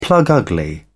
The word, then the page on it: plug-ugly: British English